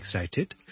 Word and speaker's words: excited: 最尾要 tid 之后翻出嚟，我先至一个完整嘅发音嚟嘅。 So I'm really excited。